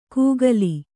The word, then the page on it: ಕೂಗಲಿ: kūgali